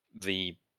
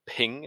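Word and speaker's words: þ: When we had our, you know, early English culture or sort of Anglo-Saxon, we used to have the ping, is it, or thing? I normally hear it as thing, but I know that Ethan has called it ping, which I assume is maybe the Saxon pronunciation. – No, it's the way it's written. It looks like a P, but it's a strange P, but it's pronounced TH. – I see. – It's like an Anglo-Saxon letter.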